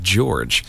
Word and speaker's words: Gyorgy: That was Katie George with something called It's Pronounced George, sort of as a nod to her last name, which is spelled G-Y-O-R-G-Y. And a lot of misconception around the pronunciation of that name.